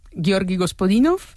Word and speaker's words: Georgi Gospodinov: Ayúdanos a pronunciar, ¿no?, los autores. – A ver, Georgi Gospodinov, sí. – Zdravka Eftimova y Vera Mutavcheva. – Ahora sí, ahora sí.